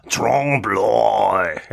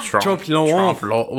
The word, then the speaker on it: trompe l'oeil: It's pronounced Trompe l'oeil. Gotta do like Trompe l'oeil.